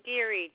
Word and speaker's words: Geary: Susan Geary, G-E-A-R-Y. – Is the first name spelled S-U-S-A-N? – Yes.